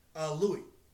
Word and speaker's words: Louis: Louis. – Louis. Okay. Because they subtitle it with the R.